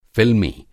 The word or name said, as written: फ़िल्मी